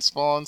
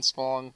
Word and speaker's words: Spohn: So you can find me on Twitter, Steven Spawn, spelled correctly, S-T-E-V-E-N, Spawn, S-P-O-H-N.